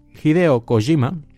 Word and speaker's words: Hideo Kojima: Hideo Kojima, o perdón, Hideo Kojima, eh, a ver si yo estando años en Japón y pronuncio mal el nombre de este hombre.